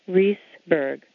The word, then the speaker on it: Riesberg: So here we have E-I, the E is long and says its name E, as in Riesberg, although one student in my fourth grade class did call me Mrs. Raspberry.